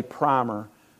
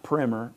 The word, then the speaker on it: primer: I say primer. It's pronounced primer. The New England Primer or primer.